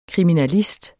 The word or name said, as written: kriminalist